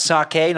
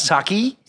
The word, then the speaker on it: sake: It's pronounced sake, not sake.